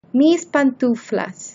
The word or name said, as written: mis pantuflas